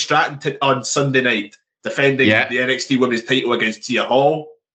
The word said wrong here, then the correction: Thea Hale: Or Tia Hale, I can't remember how you pronounce her name. – Thea Hale.